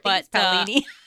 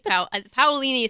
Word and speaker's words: Paolini: Paolini. – I think it's Paolini is how I've always said it.